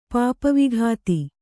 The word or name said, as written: ಪಾಪವಿಘಾತಿ